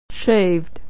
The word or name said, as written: shaved